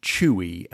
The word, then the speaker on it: Chooey: And the next episode we have, hopefully I will have got hold of some, there is a brand called Chewy, and they're in Iceland. It's spelled C-H-O-O-E-Y.